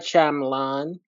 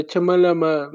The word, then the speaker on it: Shyamalan: M. Night Shyamalan. I'm not going to do the Chumalama because that's also played out.